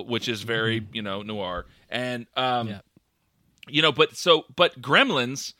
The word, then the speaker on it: noir: It had a very noir. I don't know how to pronounce that noir. N-O-I-R.